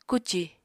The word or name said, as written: kucì